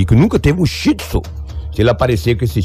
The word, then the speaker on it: Shih Tzu: Além de praticar o furto da moto, levou também o cachorrinho da, da raça Shih Tzu. Não sei se eu estou pronunciando certo. – Tá, é, tá é, certo, é, tá, é, certinho, é Shih Tzu.